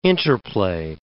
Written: interplay

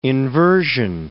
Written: inversion

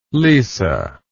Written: lisa

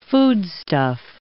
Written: foodstuff

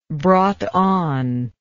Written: Broughton

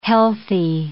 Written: healthy

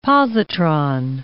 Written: positron

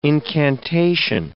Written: incantation

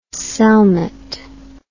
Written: cellnet

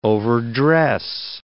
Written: overdress

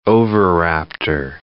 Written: oviraptor

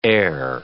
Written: air

